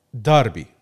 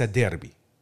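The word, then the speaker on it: Derby: طيب انا بدي اسالك سؤال كتير مهم، لانه احنا هنا البعض يمكن مرات ما بينطق الكلمه صح، داربي وليس ديربي.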